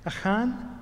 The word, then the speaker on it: Achan: Am I pronouncing that correctly? Achan? Thank you. I would have always said Achan, but then I guess that's English rather than Hebrew.